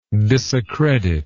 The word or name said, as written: disaccredit